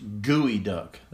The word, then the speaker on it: geoduck: I'm going to call it geoduck, but it could be geoduck, but it's pronounced gooey duck.